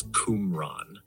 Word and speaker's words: Qumran: The word Qumran, as in the Dead Sea Scrolls, is typically pronounced as Qumran. The emphasis is usually on the first syllable, so it sounds like cum followed by ran.